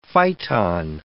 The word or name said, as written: phyton